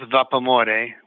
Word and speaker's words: Vapamore: Well, it's pronounced Vapamore, which is the love of steam in Italian.